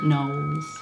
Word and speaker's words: Knollys: I was going to say Lettice Knollys. Would that be right? – Oh, yes, except you probably pronounce it Knowles.